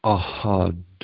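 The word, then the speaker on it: Ahad (أحد): The fourth one, the elongation is in the first syllable. Ahad. Ahad. Ahad. Ahad. Ahad. Ahad. So it's a bit of an elongation, and then the second syllable is like a two beat to a one beat. Ahad. Ahad. The H is a little bit of the throat closes just a little bit. It's not a lot.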